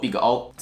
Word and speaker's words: Big O: 念作 Big O，资料结构一个连一个就是链接串列，一个连两个就是二元素，连多个呢就是多元素。